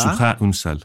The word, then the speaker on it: Σούχα Ουνσάλ: Σούχα Ουνσάλ, αν τον προφέρω σωστά. – Σούχα Ουνσάλ.